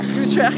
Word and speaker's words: Utrecht: Utrecht.